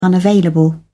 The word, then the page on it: unavailable: British English